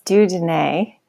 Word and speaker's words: Dieu Donné: It's Dieu Donné. It's a French name.